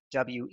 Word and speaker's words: Weinhaus: For everyone else, feel free to connect with me on LinkedIn, David Weinhaus, spelled like it sounds if you're German, Weinhaus.